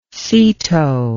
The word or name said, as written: seato